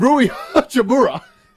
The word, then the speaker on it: Hachimura: And Rui Hachimura.